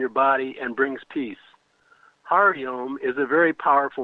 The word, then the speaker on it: Hari: Of course, when you add Hari, pronounced as the ho in hot and re as in repeat, you get an added effect.